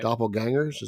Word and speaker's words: doppelgangers: Doppelgangers, is that how it's pronounced? – Gangers.